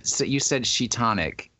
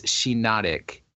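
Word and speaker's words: Shiinotic: Yeah, but you said Sheetonic. It's Sheenotic. Like hypnotic, but Sheenotic.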